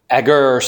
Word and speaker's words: Agur: I have heard his name pronounced. It's funny. I'm just a little bit lighthearted here. I think I've got six different pronunciations that I found for his name. Yeah. Agur seems to be the most common, so I'll stick with that.